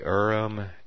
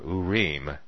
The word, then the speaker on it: Urim: The Urim, and here, look at the pronunciation here, Urim.